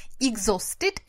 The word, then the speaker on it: exhausted: Exhausted – это изможденный. А, оно пишется неприлично, Помните, эту шутку пишется Манчестер, читается Ливерпуль. Вот это про это слово тоже.